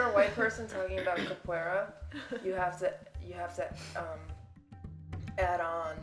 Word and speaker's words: capoeira: So capoeira. – Yeah, you have to pronounce it like capoeira.